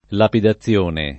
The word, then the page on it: lapidazione: lingua italiana